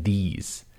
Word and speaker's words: these: A good one to practice these two different vowel sounds is this and these.